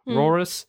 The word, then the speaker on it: Rauros: At the falls of Rauros, which I could never learn to pronounce, R-A-U-R-O-S, Rauros.